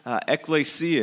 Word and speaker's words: ekklesia: The word that Jesus used here is a word, ekklesia is how you pronounce it.